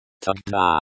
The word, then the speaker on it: тогда: Is pronounced like, тогда, with the stress on the second syllable.